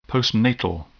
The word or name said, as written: postnatal